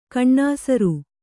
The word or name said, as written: ಘನಸಾರ